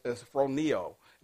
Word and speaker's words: phroneo: When we look at the word mind, but the Greek word, okay, for the word mind is phroneo. Now, you spell it, of course, the P-H sound, but it's P-H-R-O-N-E-O.